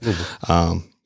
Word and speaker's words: Louisville: You got to say it like that. Louisville.